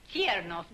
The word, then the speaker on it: Chernoff: Madam Chernoff, I... – Chernoff, darling. The T is pronounced.